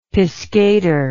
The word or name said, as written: piscator